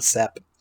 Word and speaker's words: Seppe: I think Sep is kind of become Sep. There, it's spelled S-E-P-P-E, but I think it's Sep. I think it's just said Sep.